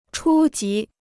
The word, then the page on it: 初级: chū jí